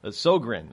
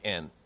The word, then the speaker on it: Sjögren: So Sjögren, I guess, is how we pronounce it in American eyes. Sjogren.